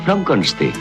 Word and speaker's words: Frankenstein: Se pronuncia Frankenstein.